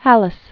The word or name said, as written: Halas